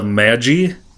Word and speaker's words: Magi: The first one I listened to was Sarak, Gift of Magi, I think is how it's pronounced. Okay. It's like magic without the C.